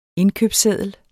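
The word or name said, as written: indkøbsseddel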